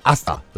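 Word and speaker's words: Hasta: Hasala Vista sind sie jetzt, wobei das H vorne weglassen soll. Da hat ein Hörer zu mir gesagt, das H gehört nicht hin, man darf das nicht aussprechen, sondern Asta, das ist da.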